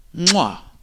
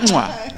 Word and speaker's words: Mwah: One guy, when I asked him how to pronounce it, like, properly in Swahili, he said, "Mwah." So you can just say. – So that's the way we pronounce the name of the band. Mwah.